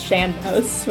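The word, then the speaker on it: sean-nós: I always call it Seanos singing. Apparently that's not how it's pronounced. Apparently it's Seanos, which I learned for the first time like an hour ago.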